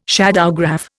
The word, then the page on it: shadowgraph: Indian